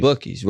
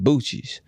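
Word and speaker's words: Buc-ee's: Bucky's, right? So this was when Bucky's was new to me, so I was calling it Bucci's. So we was at, I didn't know how to pronounce it.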